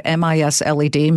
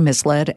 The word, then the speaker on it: misled: I mispronounced the word M I S L E D misled as misled swear to God, I did that.